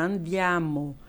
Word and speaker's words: andiamo: Às vezes eu ensino uma palavra, andiamo. É. Amo, eu sempre falo, amo. As pessoas daí estão começando, andiamo. Eu falei, não, é tudo muito aberto, é bem cantado.